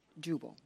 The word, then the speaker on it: Jubal: J-U-B-A-L. – Genesis 4. – Jezebel? No, not Jezebel. did human music begin with Jubal? Did I pronounce that right? – Yeah. – Yes. Jubal.